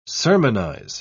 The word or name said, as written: sermonize